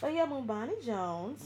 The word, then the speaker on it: Bomani: Pinky is for, how do I say his name? – Bomani. – Bomani Jones. Yes.